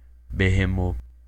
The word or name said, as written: Behemoth